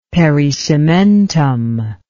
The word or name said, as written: pericementum